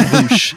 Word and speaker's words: Vaush: Don't give him the respect of pronouncing his name correctly. His name's Vaush.